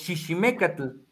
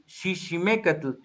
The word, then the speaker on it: chichimecatl: Un linaje que proviene de los chichimecatl. Obviamente la parte peyorativa es chichimeca. La parte como se debe de pronunciar es con X, chichimecatl.